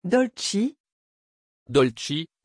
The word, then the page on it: Dolcie: French